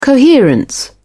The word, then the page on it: coherence: British English